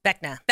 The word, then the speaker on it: Vecna: Vecna. – Vecna.